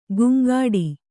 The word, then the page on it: ಗುಂಗಾಡಿ: guŋgāḍi; Kannada